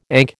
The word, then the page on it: ankh: American English